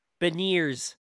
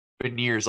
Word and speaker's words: Beniers: Matty Beerns? – Beniers. – Beniers, like veneers for your teeth. Yes, like veneers, but with a B. – Maddie Beniers from the University of Michigan.